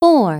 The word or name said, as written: 4